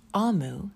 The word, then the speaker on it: Omu: Note to the reader, OMU, pronounced Amu, is the Igbo term for queen.